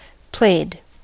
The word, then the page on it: played: American English